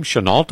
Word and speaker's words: Chennault: Chennault, am I correct? – Yeah, that's how you pronounce his name.